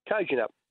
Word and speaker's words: Kojonup: Cajunup. – Spell it. – Kojonup.